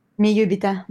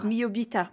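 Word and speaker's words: Mi iubita: So you're mentioning working with Naomi Merlant earlier, and you've worked with her twice now on her first film called, and I might pronounce this wrong, Mi Ibulita, mon amour, or Ibuita? – Miyubita, mon amour. – Mi Ubita, mon amour.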